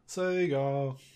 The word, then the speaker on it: Saga: Or is it Sega? Sega! – A long A.